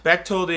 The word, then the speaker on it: Bechtold: Bechtold is German.